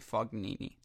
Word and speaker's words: Fognini: I was told that it's very Midwest of me to say Fognini, that it's Fognini, that the G is silent.